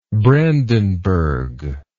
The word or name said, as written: Brandenburger